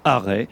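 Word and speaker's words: Arrest: Alors c'est marrant parce que ces deux garçons viennent d'une commune qui s'appelle Arrêt. Alors ça s'écrit A2RE ST, mais si vous voulez, on dit Arrêt, alors que pour eux, Arrêt, c'est le début donc de leur carrière.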